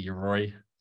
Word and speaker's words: Yoroi: Is that how you say it, Yoroi? – I say Yoroi.